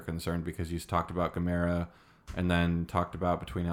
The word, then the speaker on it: Gamera: It's Gamera. Like the old school Godzilla like turtle monster.